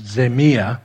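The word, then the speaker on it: zemia (ζημία): He says, I put it on your outline, these things I have counted loss And that word there in the Greek is zemia, is the way it's pronounced, for the sake of Christ.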